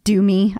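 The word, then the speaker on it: DeuxMoi: And we've seen several times on that website, whatever, the one that's like Do Me. That's how you say it, but it's not how it's spelled.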